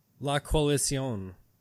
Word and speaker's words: La Coalición: La Coalición. – Oh, no one can say it right. Well, except for you.